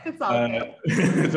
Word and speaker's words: Kelowna: Chances Casino Kelowna.